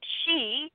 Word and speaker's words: sidhe: I would say that out of all the different traditions all over the world, the Irish have a very specific tradition, and the She is probably the easiest way to think of it, just is pronouncing it that way. But the word is spelled S-I-D-H-E.